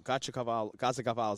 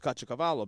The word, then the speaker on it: Caciocavallo: Caciocavallo is the Neapolitan pronunciation.